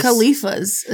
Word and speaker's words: Califas: Khalifas? – Khalifas is how I'd say it. C-A-L-I-F-A-S.